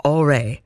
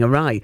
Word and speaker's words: awry: He says, years ago in school, the SWAT, when reading out loud in class, mispronounced awry with aure. Now my wife, Rain, and I found this hilarious, and years later, that's how we pronounce it now instead of saying awry.